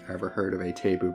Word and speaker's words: Tabu: Is it spelled taboo, but it pronounced it Tabu? – It's like T-A-B-U. – It's a massive brothel. – That's a different part of town. – It's not Tybo.